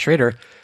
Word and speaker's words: Schrader: Going back to John Schrader and how I know that his name is Schrader, pronounced Schrader.